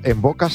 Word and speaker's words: Mvoca: Envoca se escribe M-V-O-C-A.